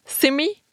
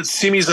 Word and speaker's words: Simi: Simi? – Yeah, Simi's a small little suburban town about 30 miles north of here, so...